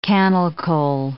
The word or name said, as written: cannel coal